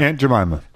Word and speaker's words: Aunt Jemima: Aunt Jemima.